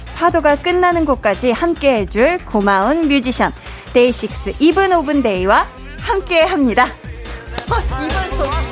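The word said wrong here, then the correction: Even of Day: 아, 이게 발음이 쉽지가 않네요. – 이븐 오브 데이.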